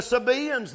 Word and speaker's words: Sabaeans: The Sabaeans there in chapter 1, verse 15. That's the way Alexander Scorby pronounced that. I always called it Sabaeans. He called it Sabaeans. And I guess he's right.